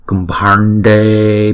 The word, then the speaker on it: kumbhande: Chữ bhand này chúng ta đọc là bh. Rồi chữ a có dấu gạch ngang là a bhande. Rồi chữ n và chữ d chúng ta cong lưỡi lại và búng ra, kumbhande bhya swaha.